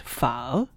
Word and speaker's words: pho: Okay, so you have to remember that it's an interrogative, okay? So it's pho.